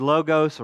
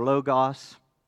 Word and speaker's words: logos: Okay, this the word here that is rendered word is logos or logos. Or pronounced both ways.